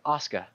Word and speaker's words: Aaska: Oh, by the way, I'm Oscar. My apologies for not introducing. – How do you spell that? – That is spelled A-A-S-K-A. – A-A-S-K-A. – I was going to say like Oscar without the R, but okay.